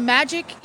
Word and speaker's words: Majyk: All right, here we are at Ada at the Magic Equip booth. And I had to ask about six times how to say that because it's spelled M-A-J-Y-K, but you spell it magic just like it looks.